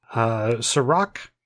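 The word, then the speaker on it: Cîroc: It's flavored popcorn, but with flavors like Jack Daniel's caramel, Ciroc vanilla. Is that how you pronounce that? – I think so, yeah.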